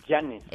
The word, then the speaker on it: Yáñez: Yáñez, con N de no.